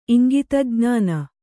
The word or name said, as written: ಇಂಗಿತಜ್ಞಾನ